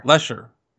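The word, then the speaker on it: Letscher: Lesher. – You don't pronounce the T.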